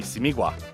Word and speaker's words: Simigwa: That would be Simigwa. Yeah, Simigwa. – It's very phonetic with African languages. It's like A, E, O, U with the vowels and pronunciation and stuff like that. – I was confused on like Simigwa, whether the G is like pronounced or not.